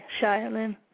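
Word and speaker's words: Shailinne: His name is spelled S-H-A-I, and Lin is L-I-N-N-E, Shailen.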